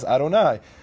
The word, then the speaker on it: Adonai: and the rabbis told them that word is pronounced as Adonai.